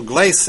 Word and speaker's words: Glace: It's Russell Glace that we have on from Boca Raton, and Russell, I apologize. I don't know where I got the other pronunciation, but we got it right.